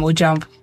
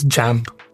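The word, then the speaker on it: JAMB: But at SS3, when we were about to take JAMP, is it JAMP? – Yeah. – Is the B silent? – Yeah, it's JAMP. – It's JAMP. – Yeah, but okay. you don't pronounce the B.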